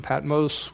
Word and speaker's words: Patmos: Now, you may remember, if you were with us in previous times, that we have read John was on the Isle of Patmos. That's how they pronounce it. They do a longo sound, Patmos, with this.